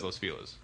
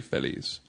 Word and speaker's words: Los Feliz: It's like it's there's a specific pronunciation in L.A. Mm-hmm. Whether it's Los Feliz, it's everybody says Los Feliz. – Right. – If you're saying if you're saying it with a Spanish accent and pronunciation, it would technically be Feliz.